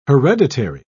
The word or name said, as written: hereditary